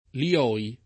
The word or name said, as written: Lioy